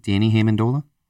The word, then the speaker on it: Danny Amendola: Danny Amendola.